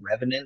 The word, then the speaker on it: Revenant: I have some friends in a band called Revenant. It's spelled RVNT.